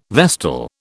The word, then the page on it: vestal: British English